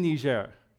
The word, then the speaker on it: Niger: and it used to be a French colony, so it's pronounced Niger instead of Niger. Niger would be the English pronunciation. Niger is the French.